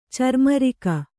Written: ಚರ್ಮರಿಕ

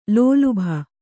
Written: ಲೋಲುಭ

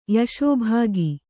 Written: ಯಶೋಭಾಗಿ

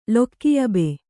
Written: ಲೊಕ್ಕಿಯಬೆ

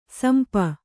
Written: ಸಿಂಪಿ

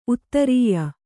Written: ಉತ್ತರಾಯಿ

